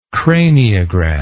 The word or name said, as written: craniograph